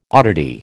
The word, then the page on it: oddity: American English